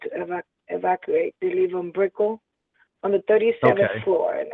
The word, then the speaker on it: Brickell: Brickell is, that's how I pronounce it, Brickell. You know, my accent.